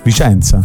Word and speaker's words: Vicenza: In questo lunedì 6 luglio abbiamo appena giocato con Antonella da Vizenza. – Da Vizenza, che si andrà a fare delle belle vacanze a Ischia, isola incredibile. – Che tra l'altro Vizenza non si dice. – Vicenza.